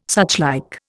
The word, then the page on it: suchlike: Indian